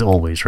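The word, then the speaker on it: Alvvays: The most recent one has got Always, and which is Always, right? You don't pronounce it with V's.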